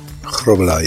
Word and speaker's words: Grobbelaar: I believe it's Grobbelaar. – Grobbelaar, yeah.